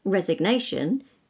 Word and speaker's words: resign: For example, R-E-S-I-G-N, resign, you don't pronounce the G. – Resignation, you pronounce the G. – And M-A-L-I-G-N, malign, you don't pronounce the G.